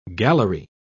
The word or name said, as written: gallery